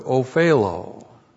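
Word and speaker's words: opheilo: In verse 11, we have a very important word there, verb, ophelo. O-P-H-E-I-L, long O. Ophelo.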